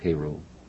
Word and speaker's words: Cairo: Cairo, Cairo. I'm pronouncing it correctly? – Yeah, you are. It's like Cairo, Egypt, but it's pronounced Cairo, Illinois.